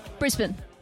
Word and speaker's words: Brisbane: The key is to go as lazy as possible with your pronunciation. – I talk pretty lazy anyway sometimes. Brisbane. – Brisbane. – Brisbane.